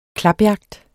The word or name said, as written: klapjagt